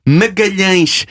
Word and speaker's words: Magalhães: Vous savez comment on dit Magellan en portugais ? Magalhães. M-A-G-A-L-H-A-E-S.